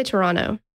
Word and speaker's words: Toronto: I just say Toronto. – Oh, Toronto. – I get rid of that T. – Hmm. – Throw it right out the window. – I like it. – Toronto.